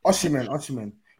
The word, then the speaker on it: Osimhen: Osimhen.